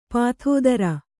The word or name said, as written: ಪತ್ತೆದಾರ